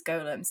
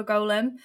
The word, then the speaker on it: golem: And, you know, you might think, oh, but isn't it golem, golem, golem? And no. That is, if you're saying golem, you're talking about a little hobbit creature who, you know, lives in the woods.